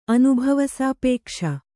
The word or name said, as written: ಅನುಭವಸಾಪೇಕ್ಷ